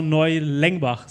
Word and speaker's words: Neulengbach: Lara, Du bist ja im Sommer aus der österreichischen Bundesliga, ist es, ähm, vom USV Neulengbach, so wird sie genau.